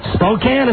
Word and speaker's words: Spokane: Spokane is how you say it.